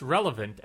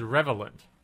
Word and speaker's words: relevant: I'll mispronounce relevant as revelant. Because that sounds like a word.